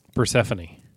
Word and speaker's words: Persephone: Persephone or Persephone? – Persephone.